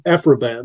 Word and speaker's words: Aphra Behn: Alpha Bane in the, is that how you pronounce it? – Aphra Bane, who was the first published female playwright in England that we know of.